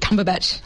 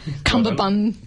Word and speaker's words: Cumberbatch: Benedict Cumberbatch. Cumberbun.